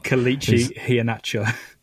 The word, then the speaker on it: Kelechi Iheanacho: Kelechi Iheanacho.